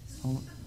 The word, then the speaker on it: Ptolemais: Ptolemais. Oh, that was Amadeus. Oh, okay, I'm sorry. It rhymes, rhymes, with, rhymes with Amadeus, uh, Ptolemaeus, and we and, and we greeted the brothers and stayed with them for one day.